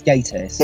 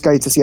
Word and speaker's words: Gatiss: Am I mispronouncing when I say Gatiss? – Yeah, I think it's Gatiss. – It is Gatiss, yeah.